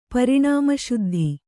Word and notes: ಪರಿಣಾಮಶುದ್ಧಿ: Kannada

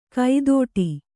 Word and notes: ಕೈದೋಟಿ: kaidōṭi

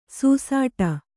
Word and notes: ಸೂಸಾಟ: sūsāṭa

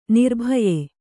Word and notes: ನಿರ್ಭಯ: nirbhaya

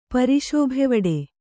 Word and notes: ಪರಿಶೋಭೆವಡೆ: Kannada

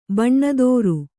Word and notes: ಬಣ್ಣದೋರು: baṇṇadōru

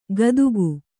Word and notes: ಕದುಕು: kannada

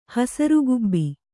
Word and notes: ಹಸುರುಗುಬ್ಬಿ: kannada

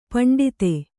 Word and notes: ಪಂಡಿತೆ: paṇḍite

